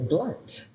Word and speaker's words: Blount: You're clearly not from the South, because if you were the South, you would say Blount.